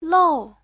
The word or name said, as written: lou4